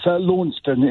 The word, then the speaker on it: Launceston: It's Launceston in Cornwall. – It's Launceston.